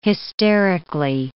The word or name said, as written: hysterically